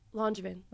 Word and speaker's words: Langevin: How do you say my last name? – Langevin. – Okay. You did, right? You avoided it. I was like, I avoided it because I've said it wrong in the past. – Yeah. – And people were like, that's not how you say Emma's last name. So. – No, you got it.